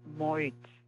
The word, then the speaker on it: Moit: So it's Elsbeth Moit. You would spell, well, okay. – Phonetically, it would spell, be spelled M-O-I-T.